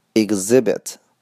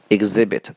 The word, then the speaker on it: exhibit: 下一个 exhibit exhibit exhibit 注意 h 是不发音的，我们单词中间的 h 往往都是不发音的。 OK 啊，exhibit exhibit 当动词讲叫做展览展示，展览展示什么东西？当名词讲叫做展品，可以变复数 exhibits。